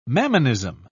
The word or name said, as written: mammonism